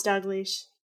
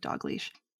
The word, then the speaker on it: Dalgleish: It's not spelled like Dogleash. It's Dalgleish? – No, it's Dogleash. – But yeah, it sounds like Dogleash.